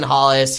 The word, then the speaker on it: Hollis: It rhymes with Basin Ballas. – Jason Mollis. – No, you got half of it right. It's Jason Hollis.